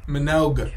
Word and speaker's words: Minogue: It's Minogue.